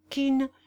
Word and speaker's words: Kean: Les pièces de Shakespeare et l'acteur Kean, prononcé Kean, furent cet événement.